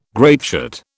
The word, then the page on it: grapeshot: British English